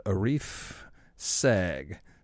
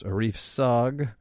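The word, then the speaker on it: Arif Sağ: It's probably pronounced Arif Sog, but you know what they say. You can take the boy out of Wisconsin, but you can't teach the boy to read Turkish names.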